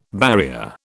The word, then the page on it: barrier: British English